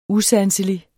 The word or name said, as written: USANSELIGE